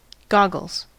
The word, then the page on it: goggles: American English